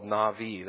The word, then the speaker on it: nabi: When you look at the Old Testament prophets in general, it comes from the Hebrew word, it's called Navi. That's how you pronounce that word, N-A-B-I, Navi.